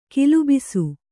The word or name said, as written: ಗುಲ್ಲೆಬ್ಬಿಸು